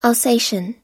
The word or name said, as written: alsatian